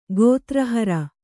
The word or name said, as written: ಗೋತ್ರಹರ